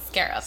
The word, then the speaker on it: Skaarup: Skaarup. – Skaarup. – Skaarup is the way. – Did I say it right? – You did. – Okay. – Yeah, the double A throws people off.